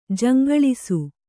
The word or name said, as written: ಜಂಗಳಿಸು